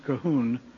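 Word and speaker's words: Colquhoun: It's spelled Culcuhoun, but it's pronounced Cahoon.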